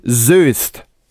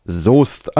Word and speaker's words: Soest: Willkommen in Soest. Mit Ö. Ne, Soest schreibt man ja mit OE. Und dann steht unter dem Plakat, so dürfen alle Leute sagen, die das erste Mal hierher kommen. Alle anderen sollten dann wissen, es heißt Soest.